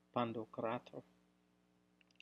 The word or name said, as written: Pandokrator